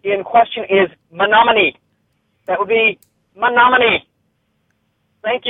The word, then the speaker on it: Menominee: Congrats on the new couple cast and the pronunciation of the Wisconsin place is Menominee.